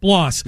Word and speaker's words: Blas: Am I getting his name right? The pitcher that threw like Blas. – One or two. Blas. – You said Blas.